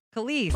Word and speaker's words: Kelis: Kelis? – Kelis.